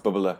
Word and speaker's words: Bubala: Please tell me I pronounced Bubbler correctly, Helen. – Yeah, you did. Not many people do, so it's mazel tov.